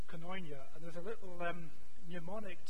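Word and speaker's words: koinonia (κοινωνία): So say coin, and there's an on switch. Coin on. Yeah. Well done, you're all Greek scholars. So this is koinonia.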